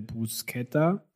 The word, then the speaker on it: Bruschetta: Äh, Bruschetta. – Das ist die richtige Art, es auszusprechen.